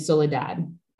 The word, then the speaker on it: Soledad: And Soledad, I hope I said that right.